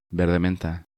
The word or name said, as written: Verde Menta